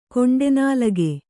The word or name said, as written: ಕೊಂಡೆನಾಲಗೆ